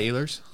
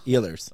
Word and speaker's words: Ehlers: Nikolai Ehlers. – Ehlers.